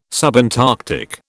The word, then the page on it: subantarctic: British English